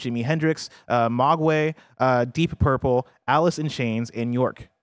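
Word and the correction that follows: Björk: Bjork? Barry, how do I say it? B-J-O-R-K. – Bjork.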